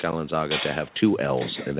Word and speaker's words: Kalenzaga: And Pierre Kalenzaga. – Exactly. It actually is pronounced exactly how it looks, but it's such a long name that it looks more confusing than it actually is.